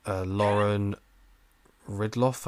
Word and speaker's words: Lauren Ridloff: Lauren Ridloff, I think is how you say it.